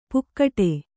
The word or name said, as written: ಪುಕ್ಕಟೆ